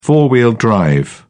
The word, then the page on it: four-wheel drive: British English